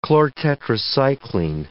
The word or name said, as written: chlortetracycline